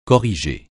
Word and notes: corrigé: francais

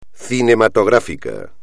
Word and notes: cinematográfico: espagnol

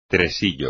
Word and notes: tresillo: espagnol

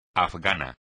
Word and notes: afgano: espagnol

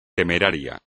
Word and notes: temerario: espagnol

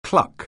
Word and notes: cluck: anglais

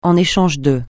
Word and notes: en échange de: francais

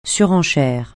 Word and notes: surenchère: French

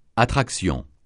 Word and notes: attraction: francais

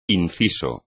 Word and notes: inciso: espagnol

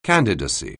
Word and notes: candidacy: anglais

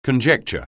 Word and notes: conjecture: anglais

